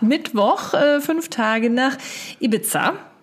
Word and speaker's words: Ibiza: Die Frau äh, da bei Google, die das ausspricht, sagt auch Ibiza.